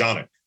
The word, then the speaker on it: Canik: So technically in Turkish, it's pronounced Canik.